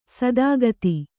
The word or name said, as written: ಸದಾಗತಿ